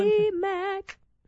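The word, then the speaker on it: TobyMac: Toby Mac. That's how you're supposed to say his name.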